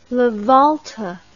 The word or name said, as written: lavolta